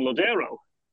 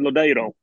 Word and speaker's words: Lodeiro: Um, player that now plays for Orlando had several years at Seattle, uh, Nicholas Lodeiro for years, people were calling him Lodero, and he had done um, an audio pronunciation where he said himself Nicholas Lodero, but actually his preference is Lodero.